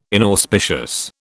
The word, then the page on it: inauspicious: British English